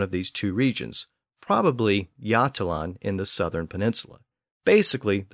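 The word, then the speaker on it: Götaland: The southern portion of the actual Scandinavian peninsula is called Jutland, and it's spelled G-O-T-A-L-A-N-D, which reflects an earlier pronunciation with an initial G sound, so it would have been more like Gotland. But there's also a large island to the east of the peninsula called Gotland, spelled G-O-T-L-A-N-D. So even though the names of the two regions are pronounced differently today, they have almost identical spellings.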